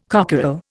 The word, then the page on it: cockerel: Indian